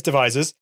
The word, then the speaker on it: Devizes: And you're from, I actually do know how you pronounce this place. Is it Devizes? I think it is Devizes.